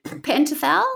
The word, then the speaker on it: Pentothal: Sodium pentothal? – Pentothal, yeah.